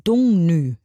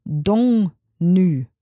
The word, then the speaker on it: Dagny: Also sie wird ja geschrieben D-A-G-N-Y. Und mein deutsches Gehirn macht daraus ja Dagny, was einfach richtig krass falsch wäre, weil sie eben nicht Deutsche ist, sondern aus Norwegen kommt. Dungnü wird das hoffentlich ausgesprochen.